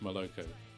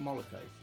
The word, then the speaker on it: Moloko: I get really annoyed that's pronounced Maloko. – Is it Moloko? – Yes. – Right. Okay. – Like the cocktail.